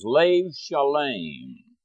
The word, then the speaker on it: lev shalem: It has to be, the Hebrew is lev shalem. And lev is the Hebrew word for heart. The B is pronounced like a V after a vowel.